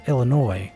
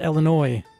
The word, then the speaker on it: Illinois: They are from Prospect Heights, Illinois. It's pronounced Illinois, guys, not Illinois.